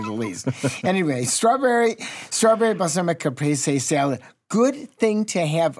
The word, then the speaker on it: Caprese: Now, let me get it correct. – Say it right. – Caprese. – There it is. – Caprese.